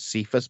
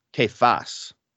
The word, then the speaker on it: Kephas: or the way I've always pronounced this was Cephas, but I actually looked it up. I've been pronouncing it wrong my whole life. He says, you are Kephas, but that word means that's one of the words that we translate as rock.